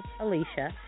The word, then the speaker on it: Alesha: And my first name is spelled A-L-E-S-H-A, Alicia.